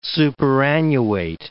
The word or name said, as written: superannuate